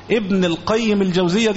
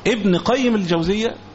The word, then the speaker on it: ابن قيم الجوزية: ولذلك لما بعض إخواننا لما يجي أحيانا ينطق ينتبه ليه، ابن القيم الجوزية دي خطأ، ابن قيم الجوزية أو ابن القيم، كلمة قيم يعني أستاذ كبير ناظر ونحو ذلك.